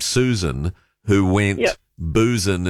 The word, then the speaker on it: Busan: I was disappointed when I discovered that the South Korean city of Busan was pronounced Busan because it's spelt the same as Susan but with a B, and I just loved the thought that there was a city named Busan.